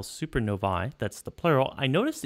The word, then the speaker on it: supernovae: So as a Latin minor, I can tell you the correct pronunciation is I supernovae. It's classical Latin.